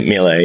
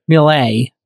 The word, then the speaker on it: Miele: This thing, this Miele, or Miele, I don't know how to pronounce it. – Yeah, Miele, yeah. – Miele.